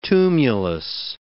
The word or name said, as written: tumulus